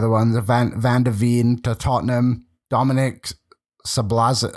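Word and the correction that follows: Szoboszlai: Oh my goodness, why am I even trying to say his name? You know who I mean. Zaboslia. That's how you say it.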